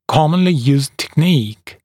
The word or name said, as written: commonly used technique